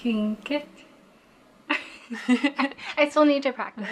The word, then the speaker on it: Tlingit: At KTOO, we usually pronounce it Tlingit, but in a story that's all about pronunciation, I gave the real way a try.